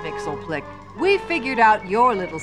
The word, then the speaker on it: Mxyzptlk: The proper pronunciation is Mr. Mxyzptlk.